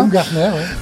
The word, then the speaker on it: Baumgartner: Valéry Giscard d'Estaing succède à Wilfried Baumgartner, j'espère que je prononce bien. – Baumgartner, oui.